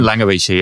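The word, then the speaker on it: Langewiesche: A big article that we're going to use a lot in this is from, I know you love this guy, William Langfrischers. I can never pronounce his last name. – Langovici, yeah, he's amazing.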